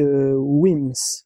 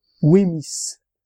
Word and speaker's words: Wemyss: Et cette petite perle est concoctée par la famille Wims, enfin, ou wimis. C'est écrit, enfin, ça s'écrit W-E-M-Y-S-S.